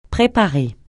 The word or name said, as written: préparer